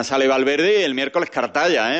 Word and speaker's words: Cartaya: Anda, Cartaya, qué bien lo pronuncia, hijo. – Verdad que sí, con todas las letras.